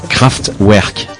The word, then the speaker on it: Kraftwerk: Alors, ils sont allemands, comme le compositeur de cette musique d'ailleurs, et leur groupe s'appelle, alors attention à la prononciation, Kraftwerk.